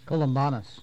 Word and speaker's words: Columbanus: After Columba, how do you say that? Columbanus? – Columbanus.